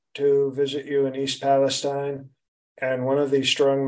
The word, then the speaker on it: Palestine: First, let's just um, you, you visited East Palestine, Ohio. – It's pronounced Palestine.